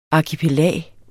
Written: arkipelag

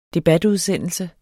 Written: debatudsendelse

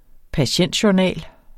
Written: patientjournal

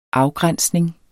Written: afgrænsning

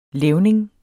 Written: levning

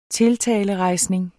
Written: tiltalerejsning

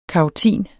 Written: karotin

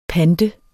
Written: pant

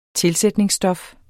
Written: TILSATTES

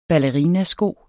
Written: ballerinasko

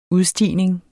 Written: udstigning

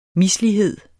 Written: mislighed

